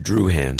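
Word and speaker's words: Drouhin: That's pronounced Druhan. – Druhan. That's what I said.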